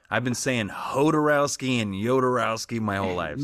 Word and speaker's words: Jodorowsky: So I've always done Jodorowsky. – He himself pronounces it Zh with a breath SH. So it's Jodorowsky.